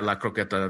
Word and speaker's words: croqueta: Yeah, La Croqueta. Very good, Brian. Very good pronunciation.